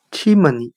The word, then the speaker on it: chimney: 我们可以用谐音法来记忆这个单词 chimney，它的发音很像汉语的气沫你，气体的气，淹没的没，你自己的你。